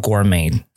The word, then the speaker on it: gourmet: 那 Gourmet 就是美食的意思，G O U R M E T，小心一点，这边的。发音啊，Star Gourmet，好，Complimentary 可以自己做选择。